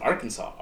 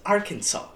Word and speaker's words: Arkansas: Kansas and Arkansas are spelled the same. – Yeah. – But it's Arkansas. It's not Arkansas. Yeah. – It's a silent S.